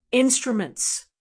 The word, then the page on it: instruments: American English